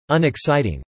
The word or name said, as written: unexciting